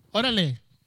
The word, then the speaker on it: Órale: Say it right. – Órale. – That's right.